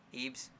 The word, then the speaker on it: Ebs: Ebs.